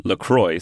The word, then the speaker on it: LaCroix: You pronounce the name LaCroix, so not French, because it's the family surname, but then you pronounce Pomplemousse, you pronounce it French.